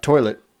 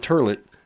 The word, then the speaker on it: toilet: Not toilet. Not turlet. Toilet.